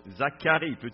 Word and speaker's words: Zacharie: Vous reculez un livre, vous allez trouver Zacharie, oui, ça c'est la meilleure prononciation.